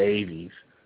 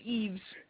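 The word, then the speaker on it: Eaves: I always want to go Aves. I don't know why. – No, it's Eves. Even though it is spelled Aves.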